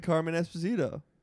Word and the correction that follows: Cameron Esposito: Cameron Esposito. But it's good for you to mispronounce a white name.